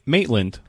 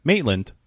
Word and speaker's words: Maitland: It's Maitland. Like, Maitland? Mating? Maitland. Yeah. Maitland.